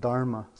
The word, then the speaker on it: Dharma: Refuge in the Dhamma is, how we pronounce it in the Theravada tradition, which is what we practice here. In the Sanskrit, Mahayana, we call it Dharma.